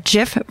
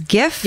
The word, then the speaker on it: GIF: I think that if they wanted it to be pronounced GIF, they probably should have gone with a J instead of a G.